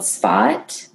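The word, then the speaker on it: Tzfat: It's called Sfat. It's spelled T-Z. Mm-hmm. F-A-T, and it's like the artist capital of Israel, and it's just like cobblestone corridor type things.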